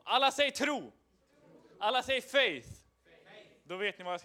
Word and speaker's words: faith: Alla säger faith. faith. tro. Alla säger tro. Det är lite enklare. Eh, det engelska eh, ordet faith är lite svårt att uttala, precis som youth med TH.